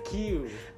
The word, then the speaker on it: queue: It's how in the word Q, Q-U-E-U-E, you only pronounce the first letter. It's just Q.